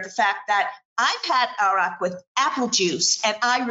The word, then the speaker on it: Arak: I think in Israel, it's called Arak. Arak, sorry. It's my bad pronunciation. But Arak is, think about ouzo.